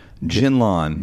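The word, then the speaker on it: Jinlon: Chromebooks said it, and so that's what it is. It's pronounced Genlon. – But I mean, it's J-I-N-L-O-N.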